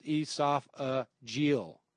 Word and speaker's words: esophageal: By the way, esophageal is pronounced esophageal. Oh, G-L. Esophageal. Oh, okay.